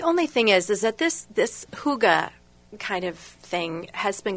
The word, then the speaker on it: hygge: And it's spelled H-Y-G-G-E. Hygge.